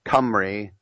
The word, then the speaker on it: Cymru: They call it in Welsh Cymru. And Cymru is spelled like Cymru, C-Y-M-R-U.